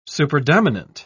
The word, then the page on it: superdominant: British English